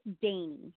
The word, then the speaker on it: Dani: It's Dani. Dainty, like okay. Danish or dainty.